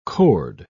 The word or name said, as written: cord